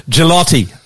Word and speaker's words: Gigliotti: Gigliotti. That's how I'm going to say it.